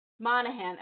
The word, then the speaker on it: Monahan: Monahan.